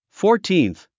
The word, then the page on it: fourteenth: fɔ`ː r tíːnθ; フォーティーンス